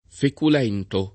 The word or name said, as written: feculento